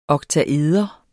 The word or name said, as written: oktaeder